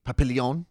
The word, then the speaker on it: Papillon: Pap- it's French, so it's probably like papi- papillon.